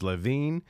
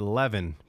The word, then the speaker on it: Levin: I had to look up how to say his name because I wasn't sure if it's Levine or is it Levin, like that, like Devin, but Levin. So it is Harvey Levin.